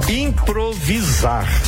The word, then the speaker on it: improvisar: Improviso, aí você está ouvindo a pronúncia com S, não é? Improvisar, não dá o som de Z? Improvisar, mas é escrito com S.